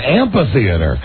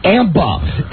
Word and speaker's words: amphitheater: Amphitheater. – Amba. – It is amphitheater. For God's sake, pronounce it correctly.